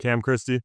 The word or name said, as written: Cam Christie